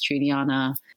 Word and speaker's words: Juliana: if it is a colleague, I'd just be like, hey, John Doe, sorry, but that's actually not how you pronounce my name. I prefer if you pronounced it as Juliana.